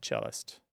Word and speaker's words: cellist: So you wouldn't pronounce the O in piano when you said pianist or the O in cello when you said cellist.